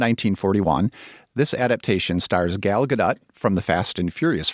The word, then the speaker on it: Gadot: We are going with Gadot because we heard her say that on Jimmy Kimmel. – Confirmed. – We can show you the link. That's how she pronounces her name.